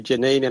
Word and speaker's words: Jayneen: And hey, that was a beautiful song by Janine. And I checked her up online. And for listeners out there, it's spelled J-A-Y-N-E-E-N.